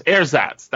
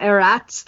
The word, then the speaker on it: Ersatz: Am I saying that word right? – Is that how you say it? Ersatz? – I think I'm saying it wrong. Ersatz? Ersatz? Ersatz. Ersatz. That's how it is. Ersatz. – Not Eratz, then? – There's an S in there.